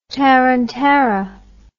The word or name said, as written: tarantara